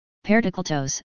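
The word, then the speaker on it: Perdikletos (Πρωτόκλητος): In the Orthodox tradition he is referred to as the first called, Pyro Omega Tau Kappa Lambda Eta Tau Omicron, pronounced, Perdikletos, because he was the first disciple to be called by Jesus to follow him and to do his work.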